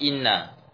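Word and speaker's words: inna (إنّ): Kalau cara bacanya anna atau inna tinggal melihat hamzahnya. Kalau hamzahnya dibaca di bawah berarti bacanya inna. Kalau hamzahnya di atas berarti bacanya anna. Ya kan? Ya, seperti awa akhwatu inna. Hamzahnya di bawah dibaca inna. Hiya hamzahnya di atas dibaca anna.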